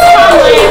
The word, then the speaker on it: Trembley: It's Trembley, but you can say whatever you want.